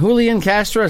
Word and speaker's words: Julian Castro: And Julian Castro, is that how they say his name? – Yeah.